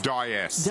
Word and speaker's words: Dyess: Raised in the town of, I'm going to say Dyess. – Dyess. – Dyess. – That's pronounced, yeah.